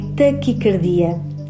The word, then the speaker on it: taquicardia: A pronúncia correta é taquicardia esta palavra pronuncia-se com A fechado e escreve-se sem qualquer acento gráfico.